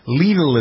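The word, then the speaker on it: Lidl: Legal is how they're pronounced. The rhymes with Beetle.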